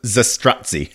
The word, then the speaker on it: Zastrozzi: It's pronounced Zastrozzi.